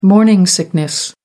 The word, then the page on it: morning sickness: American English